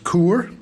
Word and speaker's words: Caoir: C-A-O-I-R, pronounced Coor, is the name of the album.